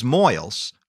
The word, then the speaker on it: Moyles: Rebecca's boarder, Henry Strait, remember Catherine's suspect, has just come in from being gone all day, along with another boarder, James Moyles, which rhymes with boils, and both run into Rebecca's room to see what all the commotion is about.